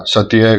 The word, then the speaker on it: Satie: Ωραία, Satie γράφεται, Satie προφέρεται, είναι Γάλλος.